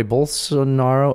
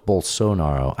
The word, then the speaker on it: Bolsonaro: I, by the way, Bolsonaro. Oh, it's Bolsonaro.